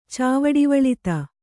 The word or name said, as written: ಚಾವಡಿವಳಿತ